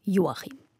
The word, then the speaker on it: Joachim: Und weil er in Ungarn geboren wurde, betont man ihn auf der ersten Silbe Joachim.